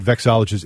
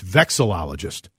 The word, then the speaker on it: vexillologist: I said vexologist. It's vexillologist.